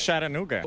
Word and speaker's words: Chattanooga: ¿Cómo se pronuncia exactamente? Y lo dicen un jamaicano, un colombiano, pero que viene en Estados Unidos. – ¿Ese nombre? – Chattanooga. – Chattanooga. – En español, Chattanooga. – Es Chattanooga.